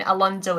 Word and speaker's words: Alonso: Yeah, Alonso, yeah.